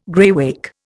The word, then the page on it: graywacke: Indian